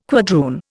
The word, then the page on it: quadroon: Indian